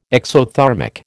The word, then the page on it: exothermic: American English